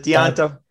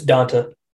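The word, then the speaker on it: D'Onta: How the hell do you say his name? Is it Deonta? – It's Donta. – Donta? That's how it's Donta? Oh, my goodness. It's Donta Foreman.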